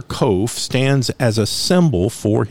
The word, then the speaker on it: kof: The verses are 145 to 152, and the Hebrew letter assigned to this section looks like a P, but it is called a kof. It is pronounced Q as in queen.